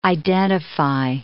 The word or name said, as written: identify